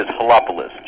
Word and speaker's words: Helopoulos: You are a little bit, but that would be par for the course when you get a last name like I do. So it is Helopoulos, Jason Helopoulos.